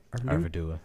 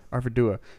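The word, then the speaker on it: Arvedui: And then it said, then later on, a few paragraphs later, it says, when Curidan heard from Arnarth, the son of Arvindui, did I say that right? – Arvidua. – Arvidua.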